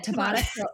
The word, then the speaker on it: Tabata: Tabata.